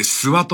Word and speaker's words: 諏訪: だから、諏訪の神様ってのが、どうもね、異種、別流の神様ではないかと。そうやって考えますと。で、これ、戸谷さんの指摘でありますけども、諏訪、地名ですよね。これ、最初読めないもんな。ごんべんに取るっていうの書いて、ごんべんにまた、方角の方と書いて、諏訪と読むんでございますね、はい。